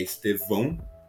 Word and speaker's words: Estevão: Mas, retornando então à Hungria, que é o nosso tema, eu até comentei sobre o rei, eu até falei, pronunciei de maneira errada, a pronúncia correta é Estevão.